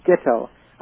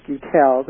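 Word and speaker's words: Guettel: And Adam, I understand his name is pronounced Gittel. I always used to pronounce it Gutel, the way it's spelled.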